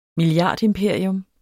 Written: milliardimperium

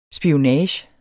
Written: SPIONAGEN

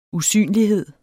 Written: usynlighed

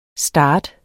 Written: starter

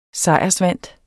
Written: sejrsvant

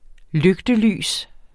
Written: lygtelys

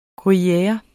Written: gruyere

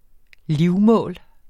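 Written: LIVMÅL

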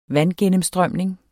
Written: vandgennemstrømning

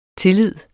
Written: tillid

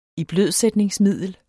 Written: iblødsætningsmiddel

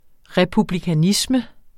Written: republikanisme

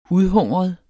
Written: udhungret

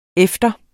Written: efter

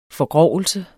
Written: forgrovelse

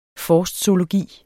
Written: forstzoologi

